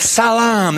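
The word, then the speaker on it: Salam (سلام): Jesus came and stood among them and said, Salam. That's how you say it in Arabic.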